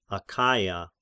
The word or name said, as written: Achaia